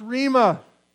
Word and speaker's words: rhema: He uses the word, and I believe it's pronounced rhema, which actually it still says word, but it means the spoken word.